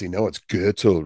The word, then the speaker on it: Goethel: Anthony S., Donnie Goethel, that's German, rhymes with metal. It's Goethel, rhymes with Myrtle.